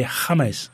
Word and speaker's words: James: His name is spelled James. – James. James. – Yeah, they pronounce it James. I think I might have said this last week. I might have repeated myself. – I don't think so. It's like Jesus.